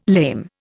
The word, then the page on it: lame: Indian